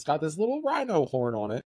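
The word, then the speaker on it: Rhighno: like R-H-I-N-O. No, it's R-H-I-G-H-N-O. So it's Rhino, like R-H-I-N-O. – I would have done like I would have done like R-E-I-N-O. – Oh, okay, I see that. Yeah. – Yeah. Rhino!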